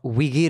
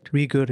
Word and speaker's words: Uyghur: The International Criminal Court refused to probe China on the alleged detention and genocide of Uyghur, is how this is pronounced, right? – Uyghur, yeah.